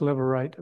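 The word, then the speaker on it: levirate: Lever, yes, lever right, Mary Shop. It's spelled L-E-V, lev, I-R-A-T-E, lever right.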